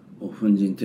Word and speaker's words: 分身: なので大日如来が根本物で、それで一切の仏様はそこからの粉塵、分身と粉塵と読むんですけど、粉塵であると、